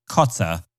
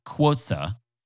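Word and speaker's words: QOTSA: Apparently, the acronym for Queens of the Stone Age is not pronounced Kotza, it's pronounced Kwotza. There's a U that's invisible there, just like there's an H that's invisible in the name Sri, which is S-R-I, if you haven't been following along.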